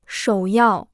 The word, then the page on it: 首要: shǒu yào